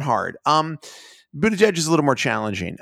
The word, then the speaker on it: Buttigieg: But I mean, only because Laura was like, I heard that Mayor Pete, which is, the name is actually pronounced Buttigieg. Is that right? – Buttigieg. – Buttigieg. That's going to be T-shirts that say boot edge edge. – It's boot edge edge, which doesn't make it easier, to be honest with you.